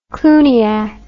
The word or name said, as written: cluniac